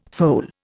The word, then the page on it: foal: Indian